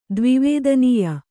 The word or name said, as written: ದ್ವಿವೇದನೀಯ